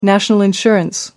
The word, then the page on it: national insurance: American English